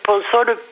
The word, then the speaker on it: Ponsol: Donc, c'est moi, je le prononce Ponsol, P-O-N-S-O-L. Je ne sais pas s'il y a deux L ou un seul.